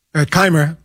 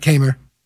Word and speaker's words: Kaymer: Kaymer. Yeah, right. Kaymer.